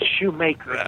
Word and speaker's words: Schumacher: You know, my mama would come after you. It's Shoemaker.